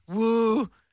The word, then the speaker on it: Worcestershire: How is Worcestershire actually pronounced? It's pronounced Woostusha.